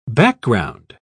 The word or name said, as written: background